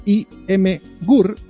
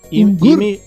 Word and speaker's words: Imgur: El video, subido a Imgur, o ¿cómo se pronuncia? – Imgur. – Imgur, ¿pero qué? ¿Imgur? ¿A dónde está Imgur? Nada, Imgur. – No, Imgur. Imgur. – Imgur. Imgur. – Ur. Imgur. Imgur. – Pero pronunciarlo de una. – Imgur. – Imgur. – Imgur. – Es como el Azure. – En Azure. Como Azure.